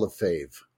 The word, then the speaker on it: Lefebvre: Yeah, so even though it's spelled, you know, L-E-F-E-B-R and a V and an E and stuff like that in there, it's just pronounced, if you listen to the characters, it's just pronounced Paul Lefebvre.